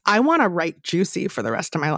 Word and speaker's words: Giusy: And it's Juicy, G-I-U-S-Y, pronounced Juicy.